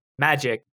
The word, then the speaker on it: Magyk: called Magic, but it's spelled M A G Y K.